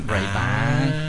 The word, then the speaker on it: Ray-Ban: Ray-Ban. – Entonces la pronunciación es Ray-Ban.